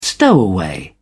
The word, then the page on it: stowaway: British English